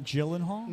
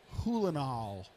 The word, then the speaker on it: Gyllenhaal: It's not Gyllenhaal? No, it's not. It's like, it's like Hulenhall. Says who? Says her brother.